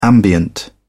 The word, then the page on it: ambient: British English